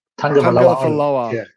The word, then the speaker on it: Tagovailoa: How do you say the last name? Tua Tagovailoa. – Tagovailoa.